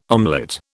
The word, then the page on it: omelette: British English